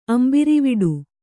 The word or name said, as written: ಅಂಬಿರಿವಿಡು